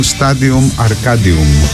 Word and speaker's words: Stadium Arcadium: Ο τίτλος ήταν Stadium Arcadium. Ακολούθησα τη λατινική προφορά, είμαι επηρεασμένος από τα πριν, από την Καρχιδόνα.